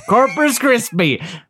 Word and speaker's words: Corpus Christi: Did you say Corpus Christi like a cereal? – I think like I said it wrong. – Every morning, Corpus Krispies to start your day right. – Corpus Christi. We went down to Corpus Christi and I got pregnant.